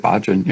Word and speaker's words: Bhajan: How do you say his name? – Bhajan. That's how I've always said it, Bhajan, yeah.